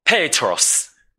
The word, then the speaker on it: Petros: 彼得念作 Petros.